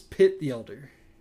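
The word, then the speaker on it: Pitt the Elder: what I mostly phonetically remember, Hit the Elder. – Super close. Enough? So I'll give it to you. – Yeah. – So it's Pit the Elder.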